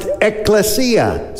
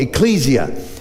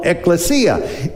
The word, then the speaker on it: ekklesia: It's a Greek word pronounced ekklesia. Sometimes in English, we'll call it ecclesia. But in the Greek language, it would be called ekklesia.